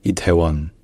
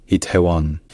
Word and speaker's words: Itaewon: Et également que le O à la fin, ce n'est pas exactement pareil que le O français, mais c'est quand même très très proche. Donc, dites juste Itaewon. Itaewon.